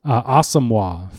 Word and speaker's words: Asamoah: And I should correct myself. We've been corrected. It's Asamoah for Brian's last name, his pronunciation. He went a whole year letting us mispronounce it.